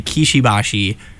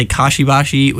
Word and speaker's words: Kishibashi: That song was Honey Body by Kishibashi. I really always have to, like, take my time with that. I always pronounce it wrong because I always want to say Kashibashi, which I think is a better name, but whatever.